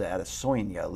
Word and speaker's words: Adesanya: You pronounce it Adesanya, Luke.